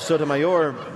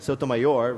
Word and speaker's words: Sotomayor: Another conservative pundit, Mark Krikorian of the National Review, complained that her annoying insistence that we pronounce her name correctly, Sotomayor, rather than Sotomayor, is an assault on good old American values.